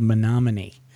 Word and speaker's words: Menominee: You know, when I grew up in a very small town in northern Michigan called Menominee. Kind of rhymes with monogamy.